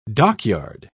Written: dockyard

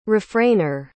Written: refrainer

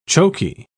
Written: choky

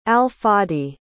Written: al-fadi

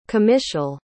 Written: comitial

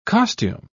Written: costume

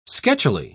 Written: sketchily

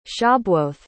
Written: shabuoth

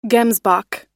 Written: gemsbok

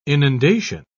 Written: inundation

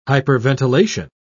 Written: hyperventilation

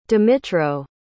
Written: dmytro

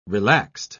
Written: relaxed

